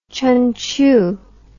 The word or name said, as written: Chunchiu